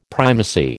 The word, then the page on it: primacy: American English